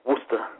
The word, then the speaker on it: Worcester: On the thing it said, last time I looked, it said Worcester, but it was spelled like W-O-O-S-T-A. Worcester.